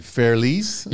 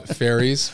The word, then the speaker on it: Fairles: Fairles.